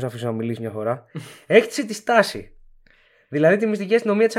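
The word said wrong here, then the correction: Stasi: Μια μικρή διόρθωση. Στάζι. – Στάζι. – Όχι στάση, ναι. Δεν στάση. Στάζι. – Στάζι. – Δεν ξέρω γιατί γράφεται στάση, διαβάζεται με ζήτα όμως.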